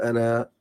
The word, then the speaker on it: أنا: زي مثال كلمة أنا او في حاله الوصل ما بتنطقش الالف في حاله الوقفه تقول انا تنطق الالف